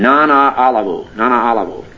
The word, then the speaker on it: Nana Olivu: Now, I've been asking the Lord, and ever since our brother Nana Olivu, is five syllables in his name, Nana Olivu. Like people's, when I find you learn the name, I like to pronounce it right. That's his Nana Olivu, that's his name.